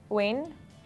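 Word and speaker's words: Wayne (وين): Arabic. Wayne, Wayne. Where is Wayne? Wayne. – Like when? – Wayne. Wayne. Wayne. W A Y N E. Is that how you spell it? Wayne. W a y n e. N e. – Wayne. Wayne. Easy. Wayne. So we have to say it five times.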